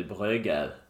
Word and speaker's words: Bredgade: Men hur uttalar man egentligen Bredgade 62? – Ja, prova. – Om, du, om jag ska följa din regel utan att, utan att uttala det, då skulle det bli Bröger.